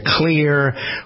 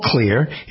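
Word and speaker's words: Xlear: There's a system from a company called Clear. It isn't spelled with a C, it's spelled with an X. X-L-E-A-R.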